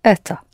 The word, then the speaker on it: Это: Это.